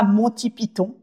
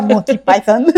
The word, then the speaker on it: Monty Python: Ici, on dira Monty Python et pas Monty Python. mais voilà, on, le prononcera à la française.